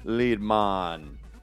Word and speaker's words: Liedman: Train Liedman for schooling us on the Via Dinarica. – Rhymes with Weedman.